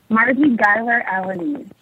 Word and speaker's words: Marji Guyler-Alaniz: Marji Guyler-Alaniz. – So it's Guyler-Alaniz. So it's just exactly what it looks like.